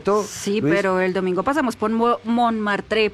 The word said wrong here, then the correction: Montmartre: perdón mi pronunciación del francés, ya sé que así no se pronuncia. – Montmartre.